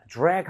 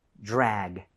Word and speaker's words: drag: 그리고 발음할 때 드래그이 아니라 TR 모음은 그때 치읓 비슷했죠. DR 모음은 지읒 비슷합니다. 입모양만 모아주세요. – 네. – 자, drag 한번 해봅니다. – 네. – Drag.